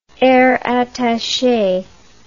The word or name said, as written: air attache